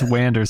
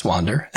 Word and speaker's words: Wander: But it's spelled W-A-N. Is that still Wander or Wander? I don't think it's Wander Song. That doesn't sound right. – Yeah, it's Wander. Like wandering around, yeah.